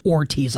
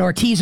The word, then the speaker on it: Hortiz: There is an exchange after the season, I believe it's at some point this summer maybe, where it's a Joe Ortiz, his name is spelled H-O-R-T-I-Z, and I believe it's pronounced Ortiz, like the H is silent perhaps. Anyway, it doesn't matter.